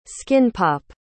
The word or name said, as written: skin-pop